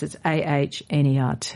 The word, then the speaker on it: Ahnert: So this really beautiful looking book, Beeswax Alchemy by Petra Ahnert, we think her name is pronounced, it's Ahnert, is certainly a gift for every beekeeper, a gift book for every beekeeper, or bee lover for that matter.